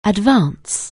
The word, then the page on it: advance: anglais